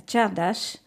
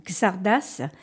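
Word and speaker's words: csárdás: Vous savez peut-être que je suis hongroise et aujourd'hui, je voudrais vous faire découvrir notre danse nationale, la tchardas, qu'en France, on prononce plutôt la gzardas.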